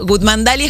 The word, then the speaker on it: Goodmandalia: Goodmandalia? – Ay, qué lindo. – ¿Cómo es? – Bueno, mi apellido es Goodman, entonces sí. Goodmandalia es como un mundo paralelo.